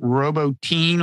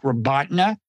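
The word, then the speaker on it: Robotyne: I pronounced it Robotin last time. I think it's more like Robotna. That's even that, I'm sure, is not 100%.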